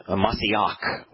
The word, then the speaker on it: Mashiach: In fact, it's interesting that there is no place in the Torah, Prophets, and Psalms where Mashiach, which is the Hebrew pronunciation, is used as a title.